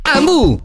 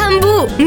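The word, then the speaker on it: Hambu: Ambu si scrive con l'H. – Ambu, mi piaci tu.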